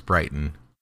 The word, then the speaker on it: Brighton: Well, it's Brighton, but in Utah, we don't pronounce our T's, so it's Brighton.